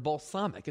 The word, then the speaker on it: balsamic: It's not how you pronounce the word balsamic. It's balsamic. It's an elegant word. The A is long.